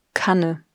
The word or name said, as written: Kanne